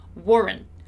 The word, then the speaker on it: weren't: And as you already know, but it's always good to remember, when we have this kind of construction, in American English, we don't really pronounce the letter T. The letter T is silent, and my ending is a very short N sound. So Warren, Warren, Warren.